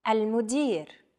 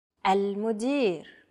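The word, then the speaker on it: Al-mudir (المدير): Al-mudir. Al-mudir. – Chances are your pronunciation was pretty spot on. The A-L-M-U-D-I and R sounds are practically identical to English. Try again. – Al-mudir.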